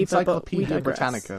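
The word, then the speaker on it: Encyclopædia Britannica: So to give a brief definition of feminism, I think at least the Britannia Is it Britannia? Or is it Britain? I can't pronounce the encyclopedia name. – You can't pronounce Ben Shapiro either. – Encyclopedia Britannica.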